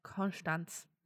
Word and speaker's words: Konstanz: Ein Konstanz. Immerhin richtig ausgesprochen.